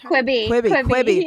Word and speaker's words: Quibi: Quibi.